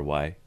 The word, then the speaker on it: Mistry: Dr. Mistry is my real name. – Is it really? – It is. – How do you spell that? – Mistry.